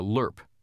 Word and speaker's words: LRRP: a LRP, as the acronym is often pronounced,